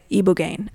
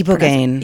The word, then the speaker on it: ibogaine: Ibogaine? How do you ibogaine. – Pronounce it? Ibogaine. – As far as I know. Although I've heard ibogaine, but the people we've spoken with, at least for the upcoming episodes, say ibogaine.